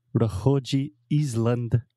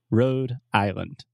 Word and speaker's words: Rhode Island: to Portuguese, like vo, oh, So road, R H O D E. So this is the same pronunciation as road, rua, and island, Island. Alexia, can you spell that one for me? – Island, I S L A N D. – Perfect. So we are not saying Rohoji Island. We're saying Rhode Island.